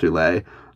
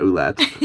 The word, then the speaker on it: Ouellet: I'm embarrassed to say that I just realized that, yes, of course that's pronounced Ouellet, not Ouellette, as I have been saying in my head for years.